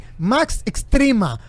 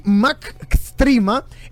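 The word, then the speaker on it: MCXtrema: Y una de ellas quiero compartir con ustedes y con la audiencia es el nuevo Maserati Max Extrema. Oigan el nombre, ¿eh? Maserati MCX Trema, así se escribe. Maserati Max Extrema es el, el nombre de la nueva bestia.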